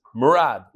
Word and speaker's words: Murad: First of all, the way he pronounced it is Murad, like Brad Murad.